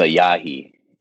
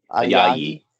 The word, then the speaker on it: Ayayi: You're closer to Ayayi. – Ayayi. I always yeah, yeah, say, yeah. I always say it wrong.